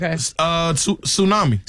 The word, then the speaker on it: tsunami: This has a silent letter in it. Tsunami.